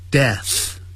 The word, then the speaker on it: deaths: 所以 death 啊是无声的 death，然后发一个 s，所以呢就这样 deaths 这样发音就可以了， deaths，不要念成 death，那不是有声的，是 deaths，就直接念成 deaths every day，每天就造成许多人的死亡。